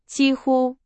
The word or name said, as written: 几乎